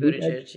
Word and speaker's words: Buttigieg: Like Buttigieg. – Pete Buttigieg, yeah.